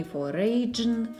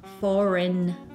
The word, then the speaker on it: foreign: Вот это зарубежное, иностранное, а по-английски будет не foreign, foreign, а foreign foreign. Да, буква G пишется, но не произносится.